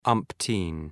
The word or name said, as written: umpteen